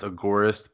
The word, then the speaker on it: agorist: I usually pronounce agorist, but some people say agorist. I just say agorist because it comes from the Greek word agora, so.